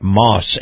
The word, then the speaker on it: Maus: Now, Moss is spelled M-A-U-S, Moss Family Automotive.